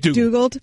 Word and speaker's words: McDougald: McDougald.